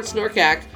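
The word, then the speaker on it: Snorkack: Snorkak. – Is that really how you pronounce it? – Snorkak? Yeah.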